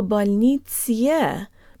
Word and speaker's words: больнице: It's very tempting to say в больнице, because our brain wants to pronounce the «е» as we say it in the alphabet, but don't do it.